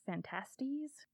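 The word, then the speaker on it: Phantastes: The actual pronunciation of this is Fantasties. – Okay. – It looks like it should say fantasy, but there's an extra T in there.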